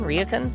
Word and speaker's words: ryokan: At ryokan? – Yeah. – You said ryokan? – Yes. Yeah. There.